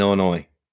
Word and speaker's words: Illinois: it's just like you don't pronounce the S in Illinois.